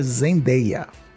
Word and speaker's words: Zendaya: Queria dizer que, infelizmente, para o meu desespero, ela deu uma entrevista dizendo que a pronúncia do nome dela é Zendaya.